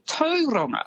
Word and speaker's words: Tauranga: And Tauranga as well is actually Tauranga.